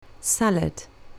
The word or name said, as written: salad